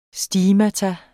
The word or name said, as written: STIGMATA